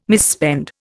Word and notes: misspend: Indian

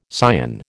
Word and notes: scion: American English

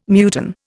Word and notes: muton: Indian

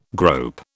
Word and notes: grope: British English

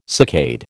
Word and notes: saccade: American English

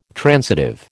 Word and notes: transitive: American English

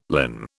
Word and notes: linn: British English

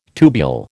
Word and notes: tubule: American English

